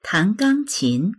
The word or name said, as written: tan2gang1qin2